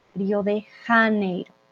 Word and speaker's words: Río de Janeiro: pero en español sería Janeiro, esa J para nosotros es, eh, viene de aquí, es fuerte, Río de Janeiro.